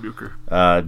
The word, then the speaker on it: Uecker: Bob Uecker, if I'm saying that right. – Yes, Bob Uecker.